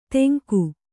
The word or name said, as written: ತೆಂಗು